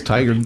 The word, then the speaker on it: Tigard: That's how you say it, right? – Tigard. Yes, Tigard. Tigard's it.